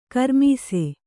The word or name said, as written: ಘೂರ್ಮಿಸು